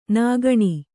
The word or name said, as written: ನಗಣ